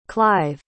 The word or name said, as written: clive